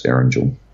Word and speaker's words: Erangel: It'll be just like racing across Erangel.